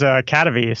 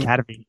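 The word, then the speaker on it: Kadavy: Cadavy. Okay, yeah. You had it. I thought you said cavity.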